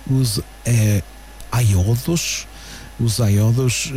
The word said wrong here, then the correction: Aioidos: João, como é que isto se pronuncia? – Aioidos. – É Oidos, ok.